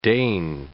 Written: deign

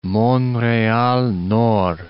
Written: Montreal-Nord

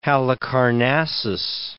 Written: Dionysius of Halicarnassus

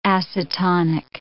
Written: acetonic

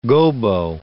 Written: gobo

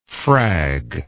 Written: frag

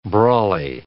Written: brawly

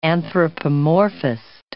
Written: anthropomorphist

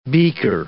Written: beaker

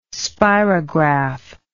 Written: spirograph

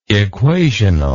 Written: equationally

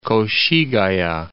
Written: Koshigaya

